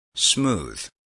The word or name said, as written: smooth